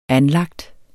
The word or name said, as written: anlagt